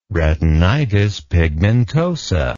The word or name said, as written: retinitis pigmentosa